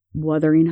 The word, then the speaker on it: Wuthering: Well, it's spelled with a U. – Wuthering Heights. – But it's W-U-R, which is were. – The R is silent. Wuthering Heights.